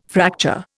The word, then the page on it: fracture: Indian